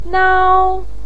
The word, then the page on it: nao1: pinyin